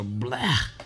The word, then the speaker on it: blegh: But it's spelled B-L-E-G-H or blah.